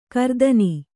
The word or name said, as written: ಗ್ರಥನ